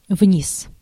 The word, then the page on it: down: /daʊn/; [daʊn]